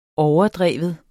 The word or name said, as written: overdrev